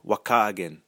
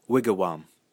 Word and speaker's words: Wakagan: Interestingly, according to Baraga's Ojibwe dictionary, house is pronounced Wakagan, or alternatively, Wigawam.